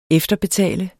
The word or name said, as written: efterbetale